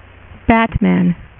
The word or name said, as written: Batman